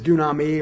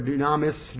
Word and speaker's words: dunamis: That word power is dunami or dunamis, depending on how you want to pronounce it, D-U-N-A-M-I-S.